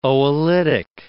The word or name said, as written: oolitic